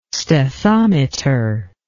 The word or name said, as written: stethometer